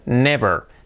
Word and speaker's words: never: Nunca, never. Fijaos la pronunciación, never. Con la V y la R. No digáis never, no, never. Nuestra letra clave R, never.